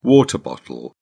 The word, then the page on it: water bottle: British English